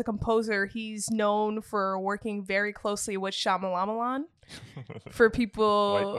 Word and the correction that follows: Shyamalan: Why you gotta disrespect the man's name like that? – Sorry for... Oh, man, I can't even say it correctly now. – M. Night Shyamalan. – M. Night Shyamalan.